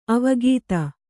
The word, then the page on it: ಅವಗತಿ: avagati